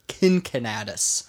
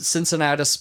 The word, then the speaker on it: Cincinnatus: Or could be actually probably pronounced Kincinnatus. – Mm. – But I'm going to pronounce it Cincinnatus because, yes, you're right, the city of Cincinnati, Ohio, is named after Cincinnatus.